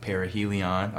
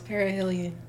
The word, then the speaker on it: Perihelion: I look out for you as far as what you do with your blog, because you have a blog called Perihelion. I'm saying it right? – No, it's Perihelion.